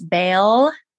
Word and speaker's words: Baal: It's Baal, B-A-A-L, the male counterpart.